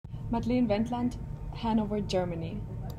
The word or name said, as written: Madlen Wendland